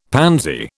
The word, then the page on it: pansy: British English